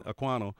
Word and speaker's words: Ekwonu: Is that how you pronounce it? Ekwonu yeah. goes one, goes one.